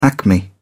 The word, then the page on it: acme: British English